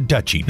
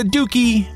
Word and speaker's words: duchy: The correct pronunciation for a realm ruled by a duke is a duchy, not a dookie.